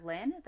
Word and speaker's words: Lynne: Myself, my Twitter, and Instagram are the same name, at MJ underscore S-A-N-T-I-I. MJ Santi, guys. Brittany? – You can find me on Twitter at Brittany underscore Lynn, that is B-R-I-T-N-I underscore L-Y-N-N-E, and on Instagram it is spelled the same way, it is Brittany Lynn without the underscore.